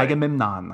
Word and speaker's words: Agamemnon: Agamemnon, I pronounced it correctly this time.